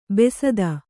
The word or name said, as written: ಬಸ್ತಿ